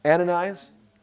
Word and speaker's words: Ananias: That's how you say it. His name, Ananias.